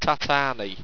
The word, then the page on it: tatani: owstwecian